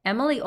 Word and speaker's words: Emily: Netflix has said that Emily Cooper's name is supposed to be pronounced with a French accent, so Emily and Paris rhyme.